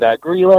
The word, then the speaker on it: Grýla: Well, my absolute favorite, her name is actually Grýla. She's from Iceland. And Grýla or Grýla, it's spelled G-R-Y-L-A.